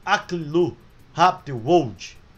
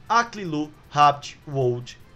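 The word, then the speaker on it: Aklilu Habte-Wold: O primeiro-ministro, o Aklilu Haptiwold, se a pronúncia for essa, eu vou ficar muito contente. Caso contrário, eu vou soletrar para você pesquisar no Google também. A-K-L-I-L-U espaço H-A-B-T-E traço Wold, W-O-L-D. Aklilu Habt Wold, primeiro-ministro, renunciou, sendo substituído pelo Endekashil Makonnen.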